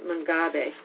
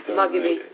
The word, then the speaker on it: Mugabe: So that was Robert Mungabe. Sorry. Mugabe.